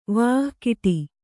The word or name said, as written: ವಿಕಟ